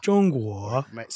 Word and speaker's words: Zhonghua: So our destination today, the Middle Kingdom, Zhonghua. – Zhonghua.